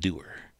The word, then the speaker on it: Dewar: By the way, there's a town in Iowa called Doer. – But it's spelled differently. – It's spelled differently. D-E-W-A-R. Doer, Iowa.